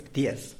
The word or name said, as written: ptiez